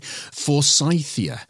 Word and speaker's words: forsythia: Now, we've got some things that are being referred to as star plants, which sound really exciting, including, and I hope I pronounce this correctly, forsythia. Tell me about this. This sounds really interesting. – That is correct.